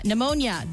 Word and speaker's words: Pneumonia: Pneumonia, the P is silent.